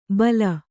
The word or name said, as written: ಬೈಲ